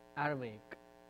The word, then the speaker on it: Aramaic: Shaitan, Satan, it's the same difference, coming from the same uh, Aramic. Did I say it right? Aramaic.